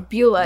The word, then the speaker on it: Beulah: Any stories about Luna catfights against, I believe, how do you pronounce it? Belva? – Maybe he'll know. – Beulah? – B-E-U-L-A. Beulah in ECW.